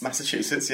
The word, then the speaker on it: Massachusetts: Massachusetts, yeah.